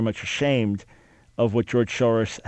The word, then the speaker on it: Soros: George Soros, pronounced Soros here in the States, but Soros in Hungary.